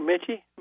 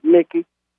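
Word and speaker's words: Michie: And do you say Lake Mickey or Mitchie? Mickey? – Yeah, Mickey.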